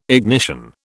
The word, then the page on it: ignition: British English